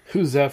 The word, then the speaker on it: Gueuze: Hooza, for those who don't know, is spelled G-U-E-U-Z-E. – It's like goose.